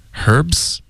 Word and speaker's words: herb: 就比如 Herbs。英式的发音会保持这个 H 音，美式的发音就会用法语的发音，因为这是法语借用过来的一个字，所以就发 herb。 – 你看、 H 音也没有了，